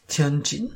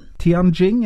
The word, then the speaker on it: Tianjin: Og den flyr mellom Oslo og Tianjin, eh, hvis det var riktig uttalt. Og det er jo Jeg tror det er ganske riktig uttaling eh, der, faktisk. Tianjin. Tianjin, ja.